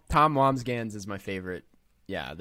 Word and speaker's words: Wambsgans: That was that was my answer and I did just pronounce it the correct way. It's Wambsgans.